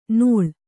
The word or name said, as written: ನೋಳ್